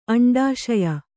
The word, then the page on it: ಅಂಡಾಶಯ: aṇḍāśaya